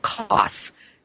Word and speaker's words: Kos: It's called Kos, like King of Swords. It's called the Kos Island, K-O-S. That's how it's spelled, and it's a Greek island, but it's not too far off of Turkey.